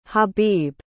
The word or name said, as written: habib